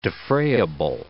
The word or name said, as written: defrayable